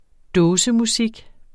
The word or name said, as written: dåsemusik